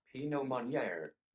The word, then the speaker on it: Meunier: How do you spell it? – M-E-U-N-I-E-R. – M-E-U-N. – Mounir or Mounir. – Menoir? – Menoir? – Yeah. Because O-I-R in French is our. – Let's see if it'll do it. – Is our. Are we going to have the computer tell us? – Pinot Meunier. – Pinot Meunier. – There you go.